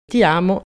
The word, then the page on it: Ti amo: italien